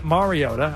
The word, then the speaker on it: Mariota: Mariota.